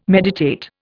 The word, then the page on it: meditate: Indian